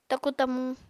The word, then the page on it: taku-tamu: apatani